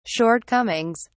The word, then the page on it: shortcomings: British English